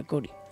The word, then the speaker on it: Chori: Se escribe Chori, pero vamos, estoy seguro de que se pronuncia Cori.